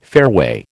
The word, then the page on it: fairway: American English